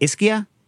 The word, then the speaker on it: Ischia: Is it Ischia? – You nailed it. – So this dude, this old dude is sitting on a bench, and behind him is like a mosaic that's like I Heart Ischia, and he's sitting on this bench like staring off on the side, and I was like, love it.